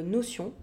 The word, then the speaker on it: Notion: Donc, Notion, ou euh, Notion, si on prononce ça euh, à la française.